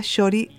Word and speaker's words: Txori: se llama Shori, se escribe T-X-O-R-I, que eso es una palabra que no es mexicana.